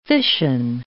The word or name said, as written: fission